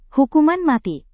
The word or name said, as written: hukuman mati